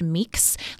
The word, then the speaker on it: Mx: So it's pronounced meeks.